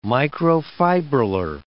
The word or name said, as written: microfibrillar